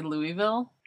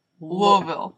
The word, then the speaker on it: Louisville: Our Kansas neighbor was from, how do you say it? Louisville? No. Louisville. – Louisville. – My tongue doesn't work like that. Lul. Like, almost like you're going to choke.